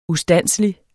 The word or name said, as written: ustandselig